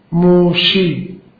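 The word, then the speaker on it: موشی (משה): این عزیر در عبری تلفظش ازراس، ازرا مثل خود موسی که عبری تلفظش چیست؟ موشی. خب به جا سین شین میگذاره.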